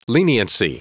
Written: leniency

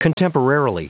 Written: contemporarily

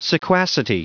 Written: sequacity

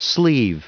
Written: sleave

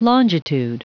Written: longitude

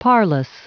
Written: parlous

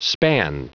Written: span

